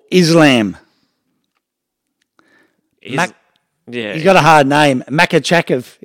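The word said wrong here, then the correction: Makhachev: isn't it? How do you say it? – Makachev. – Makachev.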